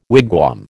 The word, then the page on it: wigwam: American English